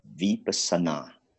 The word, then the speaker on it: Vipassana: That is Vipassana A-N-A. Vipassana.